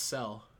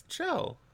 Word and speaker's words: Chell: How do you say Chell? – Correctly, it's Cell. – Cell? Seriously? – Yeah. – It's Chell. – Whoa! It's totally Chell.